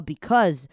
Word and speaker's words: because: them 这样子可能会帮助你发音。好，后面这个 because 也是要注意一下后面那个 c a u s e，你把它想成 a 去掉。好，然后 because 后面那个 s e 变成 z，就很像我们在打字的时候，我们打得很快哈，我们会说 because，我们不会打 because 出来，我们可能就打 c u z cause。